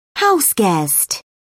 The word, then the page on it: houseguest: British English